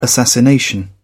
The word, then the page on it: assassination: British English